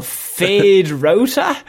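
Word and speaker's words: Feyd-Rautha: And I want you to pronounce his name. – Oh, Fade Rauta? – Yeah, that's it.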